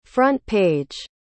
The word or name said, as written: front-page